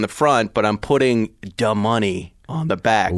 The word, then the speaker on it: Da Money: D-A, duh, M-U-H hyphen N-E-Y. Da Money.